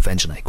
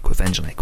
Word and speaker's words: Quvenzhané: I was literally going, Quvenzhané, Quvenzhané, Quvenzhané, Quvenzhané, Quvenzhané, Quvenzhané.